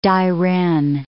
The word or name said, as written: Dairen